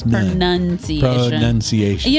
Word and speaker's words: pronunciation: Well, another common mispronounced word is actually the word pronunciation. – Pronunciation. – Yeah, because they're saying pronouncing, but there's no noun sound in there. It's pronunciation.